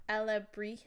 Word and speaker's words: alebrijes: I'm pretty sure it's abrijes. It's like with a J. – Um, I was like, I have the word. Alebrijes? – Yeah, alebrijes. – Alebrijes. – So I was missing a syllable.